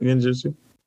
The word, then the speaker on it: genjutsu: That was why he specialized in genjutsu. Am I saying that right? – No, that's right. Genjutsu.